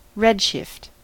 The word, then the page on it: redshift: American English